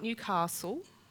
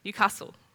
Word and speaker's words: Newcastle: He grew up in, and I need to pronounce this correctly because you can't use the Victorian pronunciation or the New South Wales pronunciation. You have to use the original pronunciation from the area, Newcastle, in England. Not Newcastle.